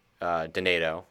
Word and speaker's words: Donato: First, it was Donato because they didn't know how to say his name. Then they realized it sounds like tomato, and so for the next 2 years, he was Ryan Tomato.